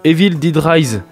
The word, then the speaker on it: Evil Dead Rise: Et on va parler aussi de Evil Dead Rise, qui marche pas trop mal. Je le dis bien, Letty? – Ouais, c'est ça.